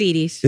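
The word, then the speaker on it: Edy's: It might be pronounced Eddie's, but whatever. – Is it Eddie's? – No, it's Edie's.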